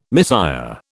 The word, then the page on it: misère: British English